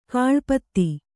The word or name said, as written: ಕಾಳ್ಪತ್ತಿ